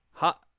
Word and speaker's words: Haute: Hot, hot, hot Butch. I, I can never pronounce that word. H A U T E. It's French.